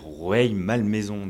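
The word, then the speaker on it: Rueil-Malmaison: moi j'ai eu un problème avec Roy Malmaison, je dis Roy Malmaison, voilà. Et en fait on dit une annonce pour un bus, il faut bien qu'on prononce. Donc j'avais du mal à dire Rueil Malmaison.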